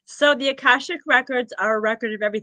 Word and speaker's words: Akashic Records: The Akashic Records.